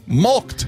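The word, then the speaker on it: mulct: M-U-L-K-E-D. Mulked. – Is. – Oh, no, it's probably M-U-L-K-T. – Is. Oh. Still wrong. – Oh. – It's M-U-L-C-T. Mulked is how you spell it. Mulked.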